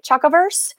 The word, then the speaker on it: Chocoverse: Amber is the author of Fake Chocolate in the Chocoverse series. Did I say that right? Chocoverse? Okay, well, good. I'm off to a good start then.